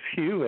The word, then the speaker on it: Hugh: my first name is Hugh H U G H.